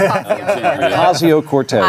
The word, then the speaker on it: Ocasio-Cortez: Octavio, how do I say it? Ocasio-Cortez.